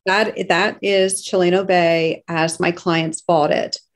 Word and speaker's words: Chileno Bay: There is a Chileno Bay, did I pronounce that correctly? – Yes.